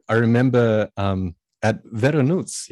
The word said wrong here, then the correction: Voronezh: yeah? Did I pronounce it? – Veronuz, Yeah, which is up near Drew the border of Ukraine. – Voronezh, yeah. Voronezh.